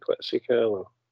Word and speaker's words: Quetzalcoatl: Is it Quetzalcoatl? – Yes.